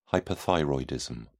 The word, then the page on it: hyperthyroidism: British English